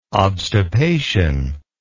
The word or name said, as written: obstipation